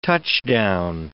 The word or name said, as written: touchdown